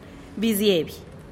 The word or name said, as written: biziebih